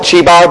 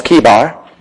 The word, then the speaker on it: Chebar: Throughout the time of preaching, I might call it Chebar because that's what I've always called it. But the correct pronunciation is Kebar.